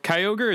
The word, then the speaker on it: Kyogre: So for is it Kyogre? Is that how you pronounce it? – Yeah. – I only know Kyogre from being a Smash Brothers Pokeball that just fucks up everything.